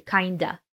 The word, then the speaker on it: kind of: And the connected speech here is kind of turns into kinda.